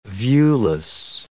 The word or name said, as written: viewless